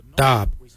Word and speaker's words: dhaab: Today we will look at, for knife we say dab. Dab is spelled D-H-A-A-B. It is a traditional knife, a dab.